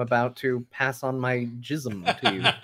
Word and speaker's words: jism: Hindi word is pronounced jism in formal speech. Yeah, so the word's been around for a while. J-I-S-M? J-I-S-M.